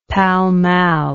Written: pall mall

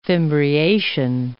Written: fimbriation

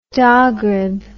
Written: Dogrib

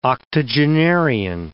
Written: octogenarian

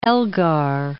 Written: Elgar

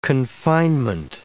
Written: confinement

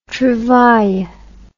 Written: trouvaille

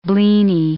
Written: blini

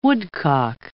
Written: woodcock

